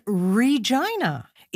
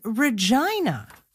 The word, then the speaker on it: Regina: The name of the capital is not Regina. It is Regina.